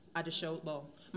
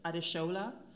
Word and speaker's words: Adeshola: So what you said about names is very correct, because basically my name is Adeshola, that's how you pronounce my name. Like, that's the Yoruba pronunciation of my name, but a lot of Western people pronounce it Adeshola, and I feel like it gives me two personas.